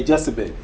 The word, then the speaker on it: Jochebed: The next, four, this name, Jochebed, am I pronouncing it well? Jochebed. So Jochebed, right? Okay, he said, yeah, Jochebed, yeah.